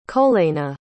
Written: koleyna

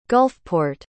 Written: gulfport

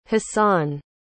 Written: hassan

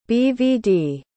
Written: bvd

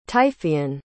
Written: typhoean

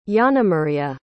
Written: yanamaria

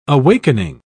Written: awakening

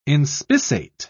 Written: inspissate

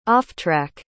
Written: offtrack

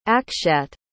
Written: akshat

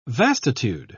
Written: vastitude

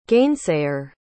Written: gainsayer

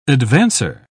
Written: advancer

